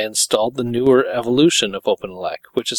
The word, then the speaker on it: OpenELEC: Am I saying that right, Dor? OpenELEC? – Yep. – Okay. – Yep, I believe so.